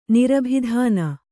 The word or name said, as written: ನಿರಭಿಧಾನ